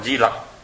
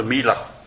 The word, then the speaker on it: Mi Lặc: Di Đà Ni thật ra chữ của nó là Mi Đà Ni, Maitrayani giống như là ngài Mi Lặc, Đức Phật Di Lặc. Bây giờ mình quen rồi mình cứ gọi là Di Lặc, chứ thật sự danh từ của nó là Mi Lặc.